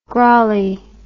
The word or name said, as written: grolley